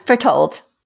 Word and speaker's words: Bertolt: Every time you say his name, it sounds like you're saying butthole to me. – Bertolt. Gotta say it right.